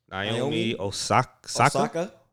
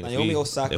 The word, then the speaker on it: Naomi Osaka: Naomi Osaka. Yeah, I mean Naomi Osaka.